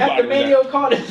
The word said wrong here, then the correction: Mano: Mano. – Mano? – Just think about it, M-A-N-O. Just Mano. Act like the I ain't there. No I in Mano.